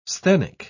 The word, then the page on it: sthenic: British English